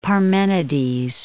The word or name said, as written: Parmenides